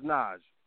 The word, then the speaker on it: Naj: Oh, it's Naj. – How do you spell that? Because I want to remember. – N-A-J. N-A-J.